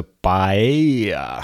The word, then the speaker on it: PAEI: вместе это читается паэя, как э, у испанцев паэлия.